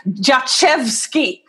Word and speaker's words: Jaszewski: no your name yachevsky you know just like it was funny so even i was pronouncing it wrong so don't so worry about it it's so the sz i thought was an sh sound that's why i said it the way i did um, so it's Russian? – No, Polish.